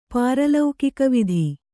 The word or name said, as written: ಪಾರಲೌಕಿಕವಿಧಿ